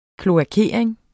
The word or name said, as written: kloakering